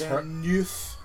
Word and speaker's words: Deneuve: It's Cathy Deneuve. I'm sure I'm saying that wrong, because she's French. But it's Deneuve.